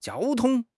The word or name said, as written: jiao1 tong1